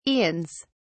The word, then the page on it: aeons: British English